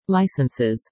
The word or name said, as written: licenses